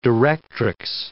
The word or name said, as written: directrix